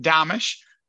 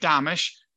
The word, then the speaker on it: Domitrz: So imagine Amish with a D in front of it, Domish. And that's actually how you say it. So it's Mike Domish.